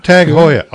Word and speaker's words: Tag Heuer: Tag Heuer? – Tag Heuer.